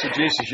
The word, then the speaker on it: exegesis: Exegesis, I guess, is how you say it. – Exegesis, you got it.